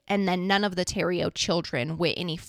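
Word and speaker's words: Thériault: But it's pronounced, I'm pretty sure, Roche and then Terrio.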